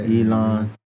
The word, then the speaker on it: Elon: Elon.